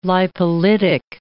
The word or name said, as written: lipolytic